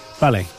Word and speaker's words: vale: Ballet. – Ballet means okay. – Mean? Oh, does it? – It's spelled V-A-L-E. Because B and V in Spanish is pronounced the same.